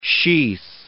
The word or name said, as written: sheath